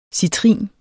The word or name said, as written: citrin